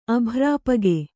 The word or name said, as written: ಅಭ್ರಾಪಗೆ